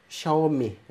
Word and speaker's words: Xiaomi: Xiaomi, herhalde doğru söylüyorum. Türkiye'ye geliyor. – Öyle dediler, öyle okunuyor dediler ama. – Kendi CEO'sunun videosunu izlemiştim de ben orada öyle duydum.